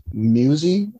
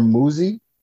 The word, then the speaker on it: Muzi: Uh, oh, it's called "I Know It" by I think his name is pronounced Musi or Muzi M U Z I.